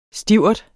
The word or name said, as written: stivert